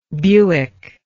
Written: Bewick

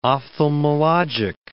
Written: ophthalmologic

